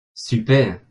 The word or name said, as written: super